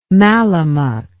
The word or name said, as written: mallemuck